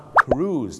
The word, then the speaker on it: cruise: cruise นั่นเองครับถูกต้องครับ cruise เหมือนทอมครูซนะฮะชาวไทยนะครับบางทีไปออกเสียงว่าครุย